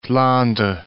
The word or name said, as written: Flandre